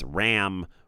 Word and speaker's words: Rammstein: Somebody said it's Rammstein, which I think is right. But another guy said it's Ramstein. So I think it's Rammstein.